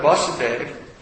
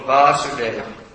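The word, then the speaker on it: Vāsudeva: Vasudev, and he's called Vasudev, long A. When is the son, it's Vasudev. When is the father, it's Vasudev, short.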